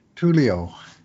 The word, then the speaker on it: Tullio: I've been watching some prospect games from a couple of Oiler forwards who were drafted, not Oiler forwards yet, but Tyler Tuoli of the Oshawa Generals, who I always mispronounce as something. Just did again. – Well, what is it? What is it? Is it not Tuoli? – Tulio. T-U-L-L-I-O.